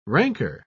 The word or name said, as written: ranker